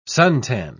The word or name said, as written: suntan